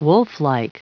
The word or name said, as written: wolflike